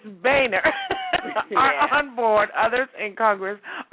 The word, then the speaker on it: Boehner: Okay, while the White House and the House Speaker John, how do you pronounce his name? Boehner. Boehner. – I always call him Boehner, but yeah, it's like Boehner. That's what I wanted to call him, but I knew it wasn't right.